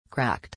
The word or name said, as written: cracked